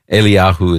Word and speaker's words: Eliyahu: It's and Eli is how, or Eli, Eliyahu is how you say Elijah.